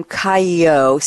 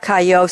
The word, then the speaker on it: Cailliau: Sara has sent me a quote from Robert, I'm going to pronounce his name Cailliau, C-A-I-L-L-I-A-U. That's my best French.